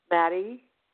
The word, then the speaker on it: Mattie: Maddie. – How do you spell that? – M-A-T-T-I-E.